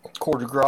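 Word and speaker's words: coup de grâce: You know, like the last two chapters, we were having this rollicking space battle, and Ejok had just done the coup de grace on the—that's the right way to pronounce that.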